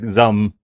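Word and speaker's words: ZA/UM: It's just Zum? A single syllable. It's like Zum.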